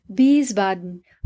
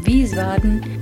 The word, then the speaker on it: Wiesbaden: Y por supuesto el nombre del lugar, Wiesbaden. Todas estas palabras tienen el diptongo IE, que pronunciaremos como una I larga.